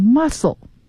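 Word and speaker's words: mussel: 量到最低的分量等等，这是 scale 的含义。那么 carbon efficiency scale 也就是在这个衡量的尺子上，carbon efficiency 到底有多么的 efficient，那肯定能耗小的它就消耗的碳就少。好，再来呢一个单词叫做 the catch，可见这个 catch 的词性呢它就不是动词， the catch， catch 是名词，它是捕获量啊，尤其适合于这个鱼捕鱼业。好，然后再来一个词，如果我念这个读音好多人就觉得这词儿不值得一讲啊，叫 muscle，它和肌肉发音一样 muscle， but it is spelled as M-U-S-S-E-L 啊，它拼写和肌肉是不一样的。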